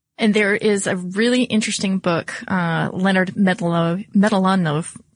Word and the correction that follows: Mlodinow: Excuse me, let me try that again, because I'm sure that at least his family would appreciate that I pronounced it correctly. But Leonard Mlodinow.